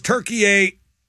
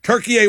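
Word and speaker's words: Türkiye: versus Turkey A. Did I pronounce it right? – Yes. – Turkey A watch party.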